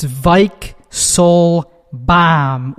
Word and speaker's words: Weichselbaum: It's Weichselbaum with a V. I don't actually pronounce it that way in real life.